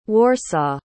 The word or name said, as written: warsaw